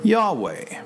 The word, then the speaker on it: Yahweh: Now, in Hebrew, the pronunciation of I Am is Yahweh.